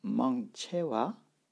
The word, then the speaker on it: མང་ཆེ: La plupart, vous connaissez peut-être Manche alors à l'écrit, ça s'écrit mangchewa, mais on ne dit à l'oral que mangche, donc c'est plus pratique.